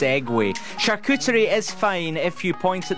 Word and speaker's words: charcuterie: Well, apparently, acai, as in the berries, you know, the healthy stuff, A-C-A-I, that's how you say it, supposedly. Seg, not sig. And charcuterie. You're supposed to say it with a French accent, not charcuterie.